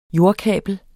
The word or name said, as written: jordkabel